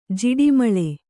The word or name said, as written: ಝಾಡುಮಾಲಿ